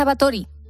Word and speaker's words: Báthory: Hoy vamos a conocer la historia de la condesa Báthory. O lo he dicho bien o lo he dicho mal, Ana. Hay dos opciones. Ana Velasco, buenos días. – Ha hecho muy bien, muy bien.